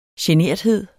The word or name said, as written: GENERTHEDEN